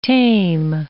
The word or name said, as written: tame